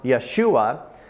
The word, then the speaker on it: Yeshua: So in Hebrew, Jesus would be pronounced Yeshua, and that word Yeshua means salvation.